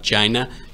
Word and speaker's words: China: I have to disagree with your analysis, not in the take of the threat level, but in your pronunciation of China. It is a hard ch- China.